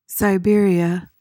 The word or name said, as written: Siberia